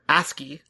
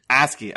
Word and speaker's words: ASCII: ASCII. – ASCII, okay, yeah, when you guys are doing that. I'm a little like, come on guys, stop it, but also like, impressed. – Yeah, well, ASCII and ye shall receive.